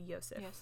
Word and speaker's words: Josef: It says, it's Josef, but when I heard people pronounce it, and I guess for Austrian, it would be Yosef. So, or like German type name.